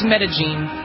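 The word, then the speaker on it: Medellín: It's Medellin. – Yeah, even though it's spelled like Medellin. Medellin.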